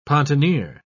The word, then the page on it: pontoneer: British English